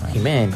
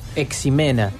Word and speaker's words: Ximena: Yo creo que acá también las Jimenas con X le decimos Jimena. – Capaz que es de Fray Bentino, es un problema. A ver, acá una oyente nos pone la X en el castellano antiguo, se pronunciaba como J.